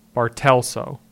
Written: Bartelso

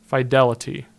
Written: Fidelity

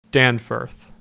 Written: Danforth

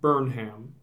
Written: Burnham

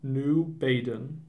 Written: New Baden

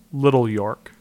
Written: Little York

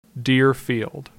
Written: Deerfield

